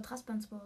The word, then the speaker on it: Trabzonspor: Ich glaube, die werden aber Trabzonspor ausgesprochen. Da ist nochmal Trabzonspor.